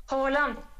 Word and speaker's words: Haaland: Haaland.